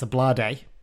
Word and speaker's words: Ablade: And then Mellish hit a long ball forward that Ablade, which I've had this confirmed, by the way, that is how you pronounce it. It's Ablade.